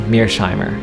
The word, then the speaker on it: Mearsheimer: His last name is spelled M-E-A-R-S-H-E-I-M-E-R. That's Mearsheimer.